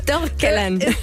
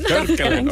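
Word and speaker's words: Törggelen: Der wird hier bei euch auch ziemlich schön zelebriert mit einem Brauch, dem Törgelen. Habe ich das jetzt richtig ausgesprochen? – Fast. – Ich hoffe. – Törgelen. – Törgelen. – Törgelen, okay. – Ja, das ist richtig.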